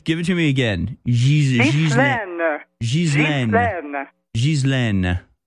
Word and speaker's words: Ghislaine: No, Ghislaine is a proper French name. I'm a former French Canadian, so Ghislaine is a correct name.